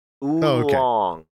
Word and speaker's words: Oolong: Oh, no, just I can't pronounce either oolong. – Oolong. – Like, oolong. – Like ooh, that is long.